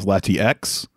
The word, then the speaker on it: Latias: Pokemon Heroes, Latios and Latias, released May 16th, 2003. Yet another Pokemon movie. I'm Delavento. – I believe the correct pronunciation now is Latiex.